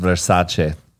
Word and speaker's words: Versace: Is it Versace? – No. – Shit. – It's actually pronounced Versace, but no.